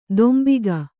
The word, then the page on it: ದೊಂಬಿಗ: dombiga